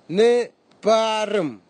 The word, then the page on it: niparim: Nafe; Tanna